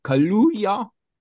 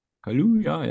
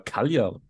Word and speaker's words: Kaluuya: Und wir haben einen Schauspieler, haben wir Daniel Kaluuya. Wird wahrscheinlich anders ausgesprochen sein. – Nein, das ist, glaube ich, gut gewesen. – Kaluuya, ja, mit 2 U.